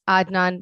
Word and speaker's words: Adnan: Adnan.